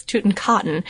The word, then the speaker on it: Tutankhaten: I'm not mispronouncing it. It's Tutankhaten.